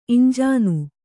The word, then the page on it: ಇಂಜೀನು: iñjīnu